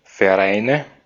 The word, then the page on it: Vereine: /fɛɐ̯ˈʔaɪ̯nə/